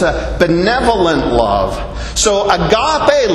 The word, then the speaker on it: agape: In this instance, it's the word agape or agape, depending on how you think the emphasis should go on the syllables. But I say agape.